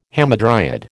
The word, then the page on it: hamadryad: American English